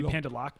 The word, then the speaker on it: pendeloque: So it's P-E-N-D-E-L-O-Q-U-E. So that's why I mispronounced it because I said pendeloque. It's actually pronounced with P-A-N. Pan-de-L-O-K. So would that be Pandeloc, maybe?